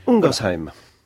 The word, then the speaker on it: Ungersheim: Alors, j'ai parlé de cette ville anglaise, Totnes, mais il y a par exemple une ville en France, Ungersheim. J'espère que je prononce bien ce mot. – Ungersheim.